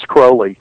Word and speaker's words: Crowley: And, you know, one of the funny things is a lot of people call him Aleister Crowley, but you actually have to pronounce it like Holy. Mm-hmm. So it's Crowley.